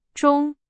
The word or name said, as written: 钟